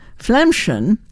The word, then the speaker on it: Flaemmchen: However, her last name is Flemchen, F-L-A-E-M-M-C-H-E-N, so it's F-L-A-E-M. That's how they pronounce Phlegm.